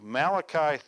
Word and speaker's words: Malachi: Let's turn over to Malachi 3, 1 through 4.